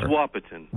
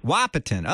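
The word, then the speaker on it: Wahpeton: Joining us for the exploration today, pastor of Emmanuel Lutheran Church in Wapeton. Did I say that right? Wapeton, North Dakota? – It's Wapeton. – Wapeton. Okay, got to know where to put the accent, right? – Exactly.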